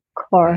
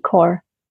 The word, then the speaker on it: Kaur: Kaur. Right. Like C-O-R-E, Kaur.